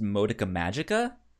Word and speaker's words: Madoka Magica: I would say, uh, I believe it's pr- pronounced Madoka Magica oh yes like that mm-hmm.